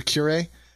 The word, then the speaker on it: curiae: All right, Josh, the reason why you are here is because this this Attorney General amicus, it's not even amicus curiae? How do you pronounce it? – Yeah, you said the second one. – Okay, so curiae.